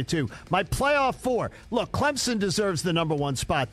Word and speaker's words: Clemson: Clemson, you've got that number one spot, and there's no P in Clemson. Down in the South, people pronounce it with a P, and there is no P in it.